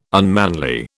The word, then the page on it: unmanly: British English